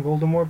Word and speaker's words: Voldemort: I'm not going to start saying Voldemort, but apparently that's how Rowling does it. – Yeah, you know, it's a silent T.